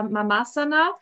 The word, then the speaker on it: Mamasana: Also tatsächlich ähm, ganz korrekt spricht man es so, wie du es eben ausgesprochen hast, aus, äh, Mamasana, ähm, ähm weil es natürlich auch von dem Wort Asana abgeleitet ist, was ja eine Yoga-Position darstellt.